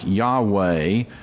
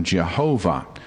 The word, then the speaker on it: Yahweh: And although scholars think that the pronunciation would probably be something like Yahweh or Yahweh, or even some scholars say Yehovah, the traditional pronunciation in English has long been Jehovah, Jehovah, and you've certainly heard that term before.